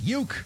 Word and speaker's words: Ueck: UECK is pronounced Uke, because it is a feature-length documentary about Bob Uecker.